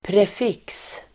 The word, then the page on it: prefix: [pref'ik:s]